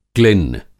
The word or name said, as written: क्लिन्न